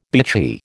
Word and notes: bitchy: American English

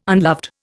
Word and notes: unloved: Indian

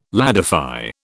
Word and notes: ladify: British English

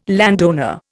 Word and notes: landowner: Indian

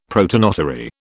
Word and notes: protonotary: British English

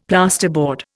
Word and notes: plasterboard: Indian

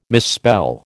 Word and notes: misspell: American English

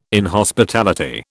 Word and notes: inhospitality: British English

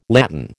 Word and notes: latten: American English

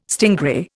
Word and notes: stingray: Indian

